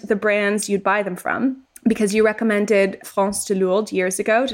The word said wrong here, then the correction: Frances de Lourdes: Did I say that right, Mon? – Uh, Frances, but but well remembered. – Frances de Lourdes years ago on Fashion No Filter, and I still love it.